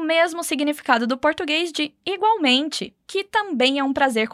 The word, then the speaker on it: igualmente: Muito cuidado com a pronúncia do L. Apesar de a gente ter o mesmo significado do português, não vamos falar igualmente, onde o L tem som de U, como igual. No espanhol, o L vai ter som de L mesmo, como na palavra lápis.